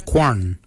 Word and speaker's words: gwan: Qualak is spelled G-W-E-L-Y-A-G. Qualak. Qualak is your hips. Your backside is your quan. Your quan is spelled G-W-A-N.